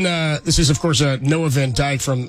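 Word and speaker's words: Van Dijk: No, it is spelled D-I-J-K, though. That's okay. – Dyke, I guess, in Dutch, maybe? – Fair enough.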